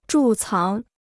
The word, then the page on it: 贮藏: zhù cáng